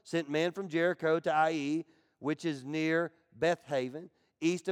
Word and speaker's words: Ai: It's A-I, but it's pronounced Ai.